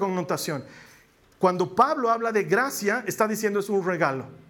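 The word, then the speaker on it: gratia: Ya de ahí en latín es gracia, Sí, se escribe con T, pero se pronuncia gracia.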